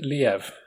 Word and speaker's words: Liev: How do you say his first name? – I think he pronounced it Liev.